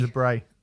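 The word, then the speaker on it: LaBrie: I mean, James LeBrie. – I think it's Brie. I think you're fine. – No, it's not. It's Bray. He's braying like a donkey.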